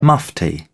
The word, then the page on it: mufti: British English